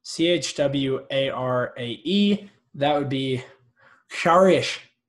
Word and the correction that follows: chwarae: It's Chuarai.